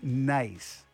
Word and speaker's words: Niece: It looks like it's spelled niece, but it's pronounced nice.